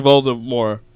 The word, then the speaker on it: Voldemort: Actually, supposedly we've all been pronouncing Voldemort wrong. – Yeah, apparently it's Voldemort. – The T is silent, sir. – Believe it or not. – Voldemort. – Voldemort. – Voldemort. – Voldemort. Like in Colbert.